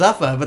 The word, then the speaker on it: Zuffa: Is it pronounced Zuffa? – Yeah, it sounds like an energy drink.